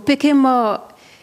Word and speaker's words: Pekemo: The second song that you have for us, am I saying it correctly? Pekemo, you might explain what it means and its origins. – So Pekemo, just straight translation would be will not die.